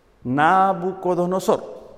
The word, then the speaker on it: Nabucodonosor: En el libro de Daniel encontramos la historia del rey Nabucodonosor. ¿Lo dije bien? Nabucodonosor. Muy bien.